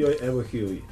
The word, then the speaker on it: Joj evo i hovi: ili kako se to kaže na, sa pazarskim akcentom? – Joj evo i hovi.